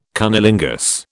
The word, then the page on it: cunnilingus: British English